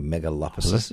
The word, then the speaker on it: megalopolises: But the great megalopolises, if I said that correctly, megalopolises. – Yes. hard word, that one.